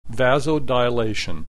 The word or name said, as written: vasodilation